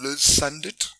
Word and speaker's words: Liz Sundet: So I managed to track down Liz Sundet, and that's how you pronounce her first name and her last name.